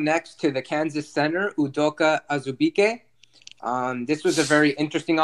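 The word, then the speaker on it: Udoka Azubuike: Okay, now moving on to Odoko Azubike. – Okay, we butchered the guy's name. Sorry. – Okay, hold on. – Try it again. – Okay, bro. Udoka Azubike.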